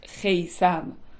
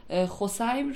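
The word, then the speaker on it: خثیم: این البته یه نکته بگم، این خسیم هم به شکلهای مختلف تلفظ میشه، بعضیا مثل میسم میگن، مثلا خیسم مثل میسم و مدل های مختلف تلفظ، ولی من خسیم رو به تبعیت از استاد شفیع کتکنی میگم.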